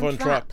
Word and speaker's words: Von Trapp: Van Trops. Van, van, Von Trapp.